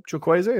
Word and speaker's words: Chukwueze: But the Chukwueze goal, Chukwueze is how you say it, right? – Yeah.